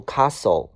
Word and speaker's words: castle: 下个单词 castle，s t t 不发音，这个单词读 castle castle 啊。